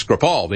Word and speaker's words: Skripal: I was calling him Skripal yesterday, I guess. That's my my southern pronunciation of these Russian names. It's Skripal. The emphasis is on a different syllable.